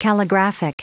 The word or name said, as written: calligraphic